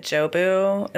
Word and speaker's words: Jobu: I think that Jobu, is that how you say it? – Mm-hmm.